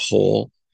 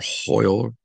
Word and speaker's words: Hoyle: But right now, I think it would be, if it goes according to, there's a term when you play cards called hole or hoyle. Some people call it hoyle. I'm trying to pronounce it better.